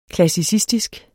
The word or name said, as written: klassicistisk